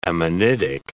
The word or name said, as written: ammonitic